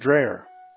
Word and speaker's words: Dreher: And again, how do you pronounce that last name? – I think it's pronounced Dreher.